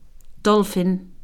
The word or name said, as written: dolphin